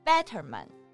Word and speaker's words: Betterman: 然后这边还强调了一下 Betterman，重音是 better，就是更好。